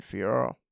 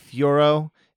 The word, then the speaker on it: Fiorot: Yeah, I think they used to say it like Fiorot, which I always thought sounded like the Pokemon Fiorot, and that was why I'd always post GIFs of Fiorot. But apparently it's Fior.